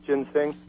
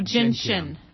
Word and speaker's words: ginseng: And ginseng, is that sometimes pronounced ginseng? – No, ginseng. Yeah.